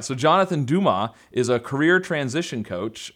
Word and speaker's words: Dumas: It's Dumas, yeah. – Dumas? – Yeah. With a silent S.